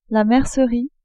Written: mercerie

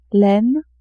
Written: laine

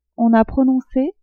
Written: on a prononcé

